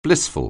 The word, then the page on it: blissful: anglais